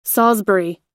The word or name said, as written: salisbury